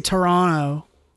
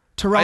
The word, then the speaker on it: Toronto: Toronto. – I say it with one T. – Toronto. – Toronto. – No, it's 2 T's. – No, it's 1 T when you say it.